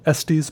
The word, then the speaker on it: Estes: Where we camped was called Estes Park. Estes? I think it's pronounced like testes without the T.